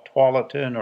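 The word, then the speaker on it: Tualatin: I hear that a lot these days, but the historically correct pronunciation is with a twa, twalatin or twality.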